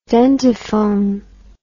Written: dentiphone

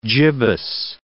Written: gibbous

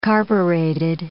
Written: carbureted

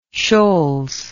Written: Sholes

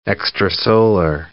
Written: extrasolar

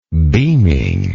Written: beamingly

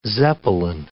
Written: zeppelin

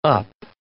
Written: up

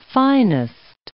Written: finest

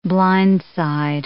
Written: blindside

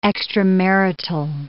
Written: extramarital

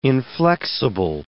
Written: inflexible

